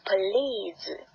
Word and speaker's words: please: 首先的第一个单词 please，请。我们要注意的是这里边的一个呃长音一的音一 please。